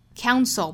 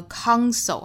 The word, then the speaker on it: counsel: Counsel 不要读成了 counsel 哈，应该是 ow 的双元音，再加后面鼻音是 own， counsel.